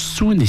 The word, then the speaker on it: Suuns: Il s'agit du groupe Soun, écrivez S-U-U-N-S, qui est un groupe canadien formé à Montréal en 2007. Le nom donc se prononce Soun et signifie zéro en thaï.